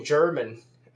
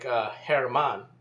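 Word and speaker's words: Germán: And by the way, what happens, that name German is really more like Herman. And what happens is in Spanish, the G, just like the H is silent in Spanish, the G sounds like the English H. It has that H sound.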